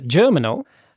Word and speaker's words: Germinal: Shall I be pretentious and keep pronouncing it in French? Germinal.